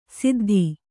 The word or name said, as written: ಸುದ್ಧಾ